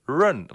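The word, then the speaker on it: rén: Ja, dus dat gelan is Nederland, maar dan staat er nog dat woordje run. Wat we uit het Engels kennen, ja, zo spreek je het uit. Maar dat woord betekent in het Chinees iets anders.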